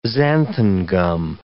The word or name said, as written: xanthan gum